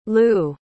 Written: lue

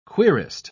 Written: querist

